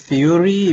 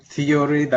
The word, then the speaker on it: Theuri: If you want to pronounce that name, Theuri, that's still fine. So I'm not practical, I'm theoretical. Theuri, okay. – Okay. – So that will help you out with the pronunciation.